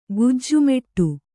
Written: ಗೆಜ್ಜೆಮೆಟ್ಟು

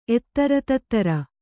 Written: ಎತ್ತಱತತ್ತಱ

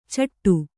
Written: ಚಟ

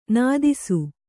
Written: ನಾದಿಸು

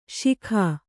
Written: ಸೈಗೆ

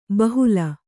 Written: ಬಹಲ

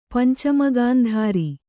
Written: ಪಂಚಮಗಾಂಧಾರ

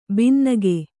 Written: ಭಿನ್ನಗೈ

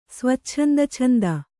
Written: ಸ್ವಚ್ಛಂದಛಂದ

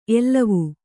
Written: ಎಲುವು

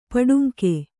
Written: ಪಟ್ಟಂಗ

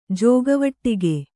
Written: ಜೋಗವಟ್ಟಿಗೆ

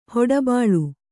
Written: ಹೊಡಬಾಳು

